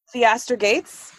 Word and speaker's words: Theaster Gates: And then Piaster Gates is someone else. I just love his work. – Is that? I didn't hear. – Theaster Gates.